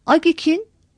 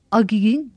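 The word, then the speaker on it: AGİK'in: Tonsuz, sert ünsüzle biten kısaltmalar, ek aldıkları zaman okunuşta tonsuz ses tonlulaştırılmaz. Agik'in, agiğin değil. Cumuk'un, cumuğun değil.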